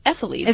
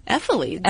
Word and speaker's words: ephelides: But the medical term for freckles are ephelides. – Really? – So, ephelides. – Is that how you, I mean, okay. – Ephelides. – Ephelides? – Yeah.